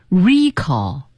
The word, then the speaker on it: recall: 我们再来看看它的名词形式，读成 recall。